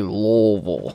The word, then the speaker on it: Louisville: Yes, Lu- Louisville. No, it's like I, uh, there's no S. – Like you have to just say Louisville.